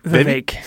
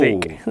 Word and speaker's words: Vivek: Vivek. – Vivek. – Like cake. – Oh. – Vivek. That's how they say it. Vivek like cake.